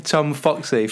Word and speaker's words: Tom Foxley: Try and say the name properly. – Tom Foxley from Mindset RX'd. – Yes, got it. Nailed.